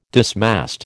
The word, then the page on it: dismast: American English